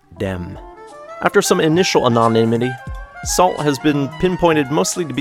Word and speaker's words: Sault: Sault, or may I should pronounce it Sault, since it's S-A-U-L-T, off the first of two untitled albums that came out this year.